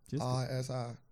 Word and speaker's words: Ledisi: Lightish, LEDISI. You got it? You know how to pronounce it? LEDISI.